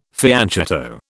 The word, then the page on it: fianchetto: British English